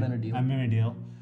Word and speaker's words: Amenadiel: Armandale, hopefully I said his name right. – Amenadiel.